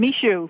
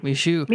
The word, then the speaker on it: Michoud: Now, I like want to make sure I say it right. Michoud, is that correct? – Michoud. – Michoud. Michoud. Yes.